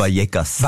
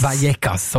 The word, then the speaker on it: Vallecas: Für das gehen wir ins kleine Quartierklüppli, kann man schon fast liebevoll sagen, Rayo Vallecano aus dem Madrider Arbeiterquartier Vallecas, oder wie spricht man es genau aus? – Vallecas. – Vallecas, so muss man es richtig sagen.